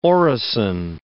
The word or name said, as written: orison